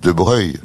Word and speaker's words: Broglie: Mais je comprends, vous dites de Broglie, alors donc. – Oui. – Abruti. – Laurent, c'est pas vrai parce que vous. – Normalement, dites... – les gens de famille disent De Breuil.